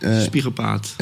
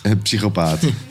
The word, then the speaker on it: psychopaat: Nee, ik ken het woord niet uitspreken, broer. Spiegelpaat. – Psychopaat.